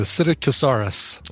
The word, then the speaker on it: Psittacosaurus: Pistacocaracus. – You just don't pronounce the P. The Psittacosaurus. – There we go.